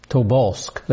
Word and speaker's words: Tobolsk: I'm not pronouncing that very well, am I? Tobolsk. There we go.